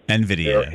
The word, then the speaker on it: NVIDIA: NVIDIA.